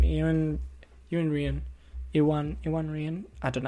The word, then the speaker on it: Iwan: No, it's Ewan. It looks the name. It's spelled I-W-A-N, but I feel like it might be Ewan. It says pronunciation Ewan.